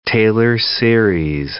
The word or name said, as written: Taylor series